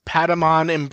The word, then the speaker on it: Patamon: It's a cream colored hat with the very nice Patamon embroidering.